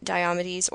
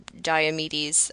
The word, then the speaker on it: Diomedes: which is the fact that Diomedes or Diomedes, we're switching back and forth. I think either pronunciation is accepted.